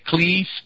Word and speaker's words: Klee: And the description that I have of McLuhan, it says, Theo says, McLuhan quips that Klee's painting is a preview of the TV aerial, an electric configuration pattern to pick up non-visual energy. – Yes, it's pronounced Klee, Paul Klee. – Klee, okay.